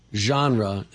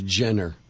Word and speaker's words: genre: They put macabre as macabre, and they put genre as Jenner. That really, they say that. That really, I'm saying no. Genre.